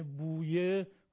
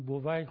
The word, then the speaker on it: بویه: خود کلمه بویه گفتن به معنای امید و آرزو هست. این کلمه در فارسی بویه تلفظ میشه اما در عربی گاهی بویه و گاهی هم بووی خونده میشه و عرب ها گاهی آل بووی رو برای این خاندان به کار میبرن.